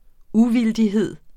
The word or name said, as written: uvildighed